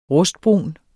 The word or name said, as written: rustbrun